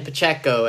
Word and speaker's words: Pacheco: Jordan Pacheco, everybody. Did I say that right or did I say it wrong? – You did. That's impressive. You said that right.